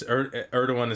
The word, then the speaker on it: Erdogan: Erdogan? – It's Erdogan is how you say it.